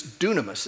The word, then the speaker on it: dunamis: It's dunamis, and it's spelled D-U-N-A-M-I-S.